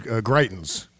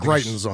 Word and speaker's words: Greitens: You say Greitens, I say Greitens. – Greitens. I'm going to go with your... – Yeah. And he says Troth Central. – Troth Central. – Right. I'm going to go with your pronunciation, which is Greitens. We're going to go with Greitens on the show.